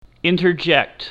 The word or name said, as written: interject